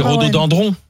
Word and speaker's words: rhododendron: Parce qu'on dit rhodo. – Dindron, c'est piégeant. – C'est dendron. Alors c'est rhododendron. – Ah bon, oui. – Est-ce qu'on y a... Ça s'écrit dendron.